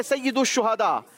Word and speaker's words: सैयदुश्शुहादा: सही प्रोनाउंसिएशन है सैयदुश्शुहादा।